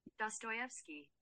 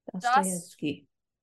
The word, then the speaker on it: Dostoevsky: Dostoevsky. – That's how you say it. – Dostoevsky.